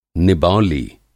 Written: निबौली

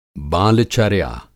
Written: बाल-चर्या

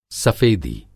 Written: सफ़ेदी

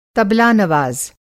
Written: तबला-नवाज़

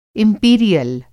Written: इंपीरियल

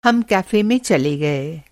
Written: कैफ़े

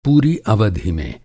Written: अवधि

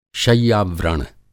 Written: शय्या-व्रण

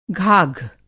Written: घाघ

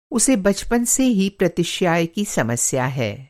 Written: प्रतिश्याय

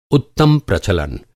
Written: प्रचलन

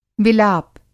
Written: विलाप

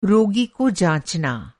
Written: जाँचना

